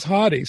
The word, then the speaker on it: Hauty: Most people say Hauty, but it is Hauty.